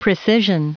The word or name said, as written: precision